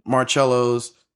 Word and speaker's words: Marcello: And, you know, it just played on to, like, you know, Marcello's, did I say that right? – Mm-hmm.